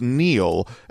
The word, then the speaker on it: Niel: Next, N-I-E-L. Pronounced Neil, and Alex, who thinks it's trendy and liberal to freeload off our hardworking patriots.